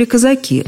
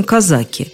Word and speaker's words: казаки: Можно сказать, что в станицу въехали казаки, а можно казаки.